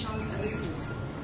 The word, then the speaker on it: Champs-Élysées: Champs-Élysées, whatever. You can never say it right. Champs-Élysées. Yeah, he's not here. But you do French? – Am I French? – No, you don't do French? – No, it's Champs-Élysées.